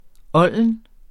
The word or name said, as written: olden